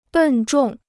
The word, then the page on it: 笨重: bèn zhòng